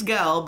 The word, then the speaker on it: Gal: And Jal Jadot. – Yeah. Is that anti-Semitic, pronouncing her name that way? – Was it? Well, her name's Gal, but her last name is Gadot, so...